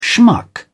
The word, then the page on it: schmuck: British English